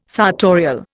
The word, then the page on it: sartorial: Indian